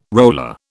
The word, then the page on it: roller: British English